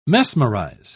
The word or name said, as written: mesmerize